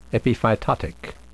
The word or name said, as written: epiphytotic